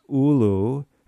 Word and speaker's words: ulu: So, but definitely what I'm hearing you say is this breadfruit, this ulu, am I saying it right? – Yes.